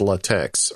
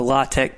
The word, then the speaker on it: LaTeX: So the root of that is tech, T-E-X, and that's the part you want to make sure you get right if you're around somebody that's really like a LaTeX purist.